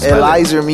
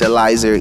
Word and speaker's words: Elyzr: Elizer Music. ELYZR Music. That's Elizer.